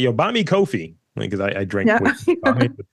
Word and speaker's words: Ko-fi: I looked up how to say it online and it said it rhymes with no fee. So Ko-fi.